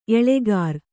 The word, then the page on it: ಎಳಗುರಿ: eḷaguri